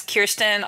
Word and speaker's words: Kirsten: Uh, it's Kirsten.